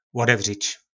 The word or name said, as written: lodevric